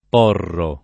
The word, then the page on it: porro: italiana